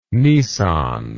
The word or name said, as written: nissan